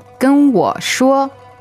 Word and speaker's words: 跟我说: 跟我说. – And you'll notice the tonal pattern is high, low, high. – 跟我说. – Yeah, that's first, third, first.